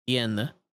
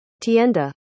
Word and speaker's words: tienda: Oh, Okay, so I said it right. Tienda. Hold on, let me. This is the. This is how you pronounce it. – Tienda.